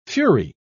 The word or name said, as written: fury